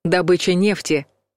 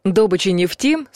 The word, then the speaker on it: добыча: Да, добыча нефти существует в природе, ее распространили нефтяники. Это сочетание относится к профессиональному жаргону и нормам русского языка не соответствует. Словари приводят лишь один вариант — добыча нефти.